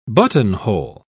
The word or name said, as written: buttonhole